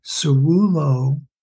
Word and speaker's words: ceruloplasmin: So the, as I understand, the best pronunciation or the ideal is ceruloplasmin. – Ceruloplasmin. – Plasmin.